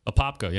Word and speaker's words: Apopka: I did my 21st birthday and I don't know if I'm pronouncing it right, Apopka or Popka, Florida. – Apopka, yeah.